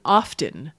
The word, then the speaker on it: often: For the last few hundred years, the pronunciation of this word, O-F-T-E-N, has been often. – Uh-huh. – The T fell out hundreds of years ago. But now some speakers are putting the T back in.